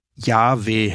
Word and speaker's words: Yahweh: In der richtigen Aussprache muss es wohl Yahweh heißen, je nachdem wie stark man das H aussprechen möchte.